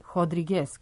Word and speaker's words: Rodrigues: ostala je Amália Rodrigues ili Rodrigues, kako se to u Portugalu izgovara.